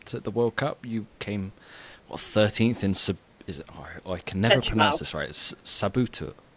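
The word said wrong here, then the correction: Setúbal: I think it's Setubal. – I can never pronounce that. Well, you placed 13th in Setubal in Portugal, which it's actually a very historic venue and a tough swim as well, looking at the conditions.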